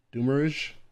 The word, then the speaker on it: demiurge: Demiurge, etc.